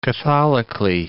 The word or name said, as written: catholically